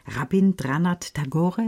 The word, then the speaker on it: Rabindranath Tagore: Max Frisch, Hermann Hesse und Rabindranath Tagore, ist das richtig ausgesprochen? – Ja, genau.